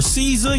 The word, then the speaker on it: Caesar: At DJ underscore Caesar, you spell it C-A-E-S-A-R.